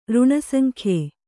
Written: ಋಣಸಂಖ್ಯೆ

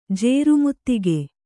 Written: ಜೇರುಮುತ್ತಿಗೆ

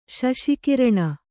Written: ಶಶಕರ್ಣ

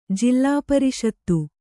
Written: ಜಿಲ್ಲಾಪರಿಷತ್ತು

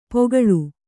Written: ಪೊಗಳು

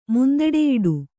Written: ಮುಂದಡೆಯಿಡು

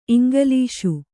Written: ಇಂಗ್ಲೀಷು

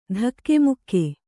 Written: ಧಕ್ಕೆಮುಕ್ಕೆ

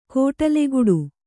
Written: ಕೊಡಲಿಗಡಿ

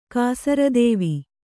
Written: ಕಾಸರದೇವಿ

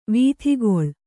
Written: ವೀಥಿಗೊಳ್

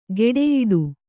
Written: ಗೆಡೆಯಾಟ

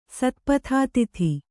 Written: ಸತ್ಪಥಾತಿಥಿ

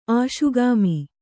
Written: ಆಶುಗಾಮಿ

